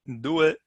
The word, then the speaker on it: due: Due.